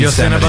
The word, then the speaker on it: Yosemite: You didn't say it right, but we know what you meant. Yeah. Yosemite. Yosemite.